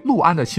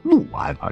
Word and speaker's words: 六安: 原来作为普通话标杆一级甲等的主播们，在播报安徽六安的新闻时，齐刷刷的竟然将六安读作六安，确实是一二三四五六的那个六啊。而在当地啊，甚至是我们以前的语文课本，在考一些易读错的字词读音的时候。六安一直是读作陆安啊，就有点像这个亳州不能念成亳州，漩涡的那个涡你得念成郭阳，不能念成涡阳。